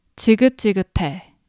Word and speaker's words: 지긋지긋해: So I'm going to pronounce this word syllable by syllable. So repeat after me. 지긋지긋해. And if you say this faster, it's 지긋지긋해.